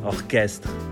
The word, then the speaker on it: orchestre: Por exemplo, orchestre, com o CH pronunciado K, orchestre.